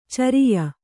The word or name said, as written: ಚರಿಯ